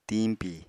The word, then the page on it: tiñpi: Apatani